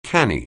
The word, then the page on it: canny: anglais